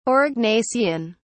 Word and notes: aurignacian: British English

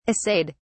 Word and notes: assayed: British English

